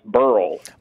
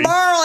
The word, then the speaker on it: Beryl: And by the way, I don't want to get off topic, but B-E-R-Y-L is pronounced Burl. – Wait a minute. Burl? – Isn't it? – If you're from Tonganoxie. – Burl, that's how you say it in Tonganoxie. Old Tonga, you say Burl.